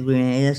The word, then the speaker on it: Grenada: come Grenada si scrive con lo spagnolo. Però la pronuncia Grenada, siccome è un'isola che appartiene all'Inghilterra, tanto per cambiare, hanno fatto un po' di conquiste.